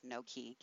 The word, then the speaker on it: Nokē: It's spelled Noke, but pronounced Nokē. Get it? No keys.